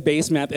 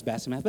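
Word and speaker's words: Basemath: Yeah, it's base math, isn't it? Right? So I'm pretty sure that base math, it's pronounced Basamath.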